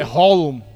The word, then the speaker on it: Haaland: É Holm. – Ah, pronúncia é essa.